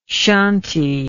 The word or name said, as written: shanti